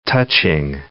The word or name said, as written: touching